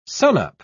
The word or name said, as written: sunup